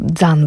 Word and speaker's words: can: I taką puszkę jeden z muzyków grupy dostrzegł w tureckim sklepie i bardzo mu się spodobała, bo zresztą słowo Can, czyli puszka, w tureckim wymawiane jako dzan bardziej, oznacza duszę, oznacza życie.